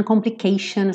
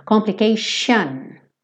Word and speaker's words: complication: Então, tem um padrão de pronúncia para essas palavras, que esse T sempre vai ser sh, sh, sh, esse som aqui, tá bom? Implication, complication. Alright? Então vocês vão ver muito esse T-I-O-N aí pintado de verde nessa música.